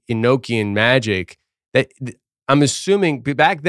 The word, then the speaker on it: Enochian: But I think it is pronounced Enochian, but then the vowel O is short. Enoch is how I've always heard it pronounced.